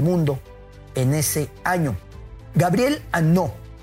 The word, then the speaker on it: Hanot: Gabriel Annaud, para que pueda encontrar bien su nombre, Gabriel Janot, lo pronuncian algunos porque lleva H al principio, H-A-N-O-T, con aquello de que en francés la H es muda, es Gabriel Annaud.